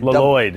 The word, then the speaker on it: Lloyd: It's like a Lloyd.